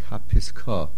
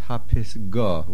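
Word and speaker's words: Hapisgah (הַפִּסְגָּה): The word is Hapisgah. Do not make it into a kuf. Do not make it sound like Hapiskah. The word is Hapisgah with a gimel.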